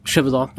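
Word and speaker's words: Gévaudan: Um, ja, og da denne, den historien, uh, beist i Gøvedan, er det uttaler? Chevedan. Ja.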